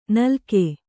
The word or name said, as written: ನಾಲಿಗೆ